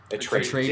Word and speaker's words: Atreides: Atreides.